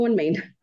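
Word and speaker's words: OneMain: And I want to start by talking, is it called One Main Financial or is it pronounced differently? – No, it's called One Main.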